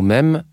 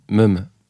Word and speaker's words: même: Étant breton, je ne prononce pas bien le mot « même », je le dis « meme ».